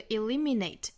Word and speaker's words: eliminate: 那我们需要注意这个 eliminate 它这个重读音节应该是在第二个音节上，也就是在 li 上面。